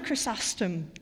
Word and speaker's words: Chrysostom: John Chrysostom.